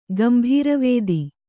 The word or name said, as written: ಗಂಭೀರವೇದಿ